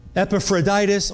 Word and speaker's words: Epaphroditus: I'm with Timothy and Epaphroditus. Oh, I said it right that time.